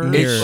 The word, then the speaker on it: Mirours: Mirrors. – That's just, you know, the normal way to say mirrors, but it's spelt the not normal way.